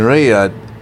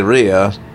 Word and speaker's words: Riyadh: And this event will be taking place in Riyadh. See if I get the name right. So I said Riyadh. Now, it's Riyadh.